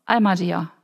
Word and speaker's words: Ahmadiyya: So, und jetzt, was sich geändert hat, bisher, so kam das ja auch, und wir sind ja hier in Erfurt, Ahmadiyya, wie spricht man das aus? Ahmadiyya, genau.